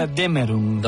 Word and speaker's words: Götterdämmerung: Aquest Götterdämmerung, ho pronuncio bé? – Götterdämmerung.